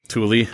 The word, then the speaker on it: Tooele: Tooele.